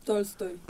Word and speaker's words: Tolstoy: ¿se dice Tolstoy? ¿Cómo se dice el cuentista? – Tolstoy.